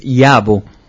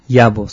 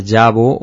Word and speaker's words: Yabo: Bueno, pues en realidad es Yabo. Yabo sería la correcta pronunciación, pero al haberlo escrito en caracteres latinos, le pusieron la Y, entonces a veces se pronuncia Yabo, o en el Río de la Plata, Yabo, o ¿Y aquí en Madrid cómo te llaman?